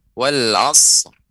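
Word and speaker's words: wal asr (والعصر): Tinggal sedikit tadi cuman wal asr itu dipanjangkan wal asr.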